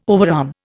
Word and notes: overarm: Indian

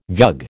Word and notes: vug: American English

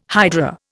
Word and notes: hydra: Indian

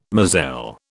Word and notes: moselle: British English

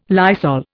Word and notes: Lysol: Indian

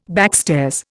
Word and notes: backstairs: Indian

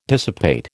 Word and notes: dissipate: American English

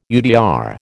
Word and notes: UDR: American English

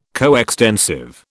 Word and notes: coextensive: British English

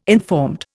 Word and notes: informed: Indian

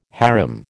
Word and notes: harem: American English

